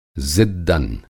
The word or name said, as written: ज़िद्दन